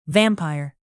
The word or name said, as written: vampire